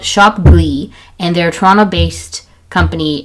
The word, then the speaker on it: Ghlee: There's this one company called Shop Glee, and so it's spelled G-H-L-E-E, so it's ghee based skincare.